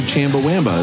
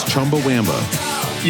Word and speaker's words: Chumbawamba: And now the problem is I pronounce it Chumbawamba, and that's not right either. It's Chumbawamba.